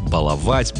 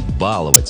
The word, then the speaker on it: баловать: Единственно верным считался вариант «баловать», «балуемся», «балованный». Вариант «баловать» с ударением на «а» помечался как разговорный. Но в 2025 году вышло сразу два словаря, которые признают равноправными оба варианта. Это большой словарь ударения русского языка и орфоэпический словарь русского языка как государственного. Так что сегодня можно говорить «баловать», а можно «баловать». Ошибки не будет.